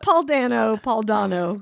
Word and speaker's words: Dano: I've been mispronouncing it the whole time, but Paul Dano collects teeth. – Nuh-uh. – Yes. Wait. – No. – Wow. It's Dono? – It's Dono.